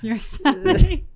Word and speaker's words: Yosemite: You just learned it's Yosemite.